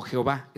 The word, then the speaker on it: Jehová: Yahvé, que se puede pronunciar como Yahweh o como Jehová, es exactamente lo mismo, por eso es una forma como tratar de decir, así lo podemos pronunciar, porque no tiene vocales.